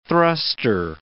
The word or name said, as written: thruster